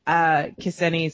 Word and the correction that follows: Ksenia: Solo. Did I say it right? – Ksenia.